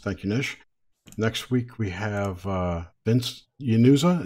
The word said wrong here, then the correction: Inzunza: Is Yanunza? Is that how you pronounce it? Anyone? Anyone? – Ren. Inzunza. – Inzunza. At least that's how I say it.